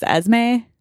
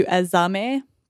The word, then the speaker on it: Esme: Even though I think that the proper way of pronouncing the name is Esme, like two syllables, Esme, as opposed to Esame. But because of the way she sings it, we, as Joanna Newsom fans, just get used to pronouncing it with the three syllables.